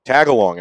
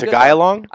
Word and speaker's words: Tagalog: Do you speak Tagalog at all? – Ooh, well, one, you said it wrong. – Oh, fuck. – Two... – What is it? – No, but that's Tagalog.